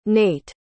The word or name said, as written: nate